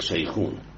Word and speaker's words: شيخان: اسمها هي شيخان، تنكتب شيخان تلفظ هناك شيخون، بوسط الجبين تماما سمع في كربين مقبرة زكريا بن آدم القمي، منزوره كلنا، كل العالم بتزوره، قبر صغير يعني.